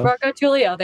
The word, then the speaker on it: Marcotullio: Natalie and I were backstage hanging out, talking about New York, New Jersey, Marcotullio. Did I say it right? Marcotullio? – Marcotullio, there you go.